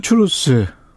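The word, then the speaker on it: truth: 예, 오늘의 키워드는 포스트 트루스. 예. 제가 추루스라는 트루스죠, 트루스. 트루스, 추루스. 예.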